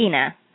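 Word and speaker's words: Inna: So, yeah, so it's Ina. – How do you? Mm-hmm. – Ina. So it's kind of like Tina or Dina with no T or D.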